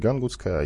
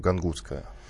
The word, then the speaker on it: гангутское: И надо уточнить обязательно, что есть несколько вариантов по поводу ударения. Все-таки кто-то говорит гангутское, а я вижу, что есть э, и ударение на второй слог, гангутское.